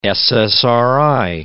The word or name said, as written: SSRI